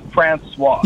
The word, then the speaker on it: François: It's François.